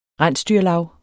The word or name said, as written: rensdyrlav